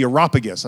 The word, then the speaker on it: Areopagus: the Areopagus, I think that's how you say it.